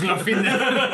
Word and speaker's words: Glorfindel: Es Gordinfeld. – No, es Glorfindel.